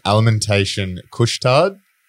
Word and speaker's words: Alimentation Couche-Tard: Alimentation Couche-Tard, Canadian convenience chain.